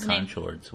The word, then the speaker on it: Conchords: Yeah. Conchords. It's pronounced Conchords.